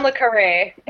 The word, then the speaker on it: Le Carré: The John Le Carré.